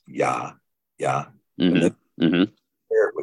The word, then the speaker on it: Yah: And they say it's pronounced Jah, but we know there's no J in Hebrew.